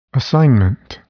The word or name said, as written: assignment